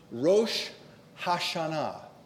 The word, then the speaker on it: Rosh Hashanah: It's called just simply the Day of Trumpets in Numbers 29:1, Yom Teruah, but the more common name for this festival is the proper pronunciation, which is really not a biblical term for the feast, it's what the Jewish people call it, Rosh Hashanah.